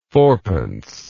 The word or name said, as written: fourpence